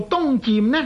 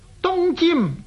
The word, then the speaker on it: 渐: 一般人呢都读做欧风东渐，个渐字读做去声，其实读做东渐呢是误读，正确的读音呢应该是东渐平声。读做尖的时候是点解呢？佢嘅字义呢即係流入咁解，尖即係流入。东尖呢个词语是出自书经与共篇嘅，佢话东尖于海，西避于流沙。